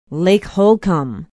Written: lake holcombe

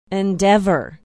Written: endeavor

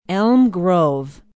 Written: elm grove

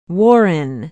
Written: warren

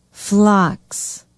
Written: phlox

